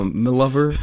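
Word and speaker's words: M'Lover: My Lover. It's just so everyone understands the confusion. It's spelled M apostrophe L O V E R. – My Lover. – I think it's like my lady.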